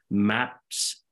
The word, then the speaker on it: MapXus: We're going to talk about a company called MapXus, I think is how you say it. It's MAPXUS. And these guys are...